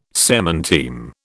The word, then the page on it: semanteme: British English